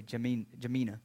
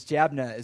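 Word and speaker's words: Jabneh: Now, I'm a hick, so I say Jamina. But it's Jabna is a way to pronounce that.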